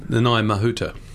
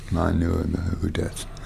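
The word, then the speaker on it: Nanaia Mahuta: Well, you were at the conference, and probably before that, you met our relatively new foreign minister, Naomi Mato. I'm probably mispronouncing her name. – Nanai Mahuta. – Nanai Mahuta.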